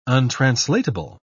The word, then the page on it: untranslatable: British English